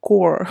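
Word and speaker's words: Kaur: Ну, вот, кстати, вот фамилия Рупи Каур, но у нас пишут Каур, да, типа она произносится как Core.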